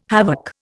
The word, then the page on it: havoc: Indian